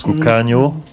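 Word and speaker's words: Cocanha: On vient d'écouter Cucagna. C'est comme ça que ça se prononce. – Cucagno. – Cucagno. – Le A, Cucagno. Ouais, Cucagno.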